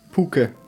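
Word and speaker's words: pûke: Wie spricht man ein U mit so einem Dach aus? – U. – Puke.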